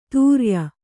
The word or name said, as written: ತುರ್ಯ